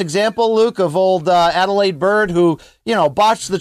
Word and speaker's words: Adalaide Byrd: Yet if there's a title fight in Texas, old Larry's there, Luke, from, you know, I want to call her because Danny Jacobs once famously mispronounced this on my podcast. I wanted to call her Adolescent Bird.